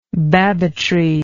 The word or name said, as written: babbitry